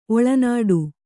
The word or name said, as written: ಒಳನಾಡು